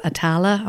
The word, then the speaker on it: Atala: Alex Atala.